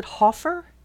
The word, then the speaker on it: Hofer: And we have a new, I'm going to pronounce it Hoffer, H-O-F-E-R, Hoffer 99, stopped in and gave 95 sats with a welcome to a fellow Bitcoiner and a plea that I continue learning about it.